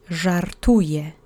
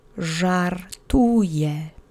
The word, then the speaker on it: żartuje: You'll need your duck face to make the ż phoneme, and the accent is on the middle syllable. Let's say it together 3 times. Żartuje. Żartuje.